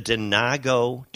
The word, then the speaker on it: Denago: Tell us a little about the Denago? – You're saying that correctly, Potato? – Denago rolled out their line this year, actually.